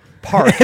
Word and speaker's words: Park: It's called pork. Holy crap. – Pork? – Park. – It's a four-letter word. – It's a four-letter and I butchered it. Park cognac. – One syllable.